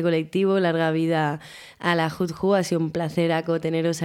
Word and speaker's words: Hood Hoe: Bueno, chicas, pues ha sido muy interesante esta entrevista, esta presentación del colectivo Hud Hou. Por fin lo digo bien.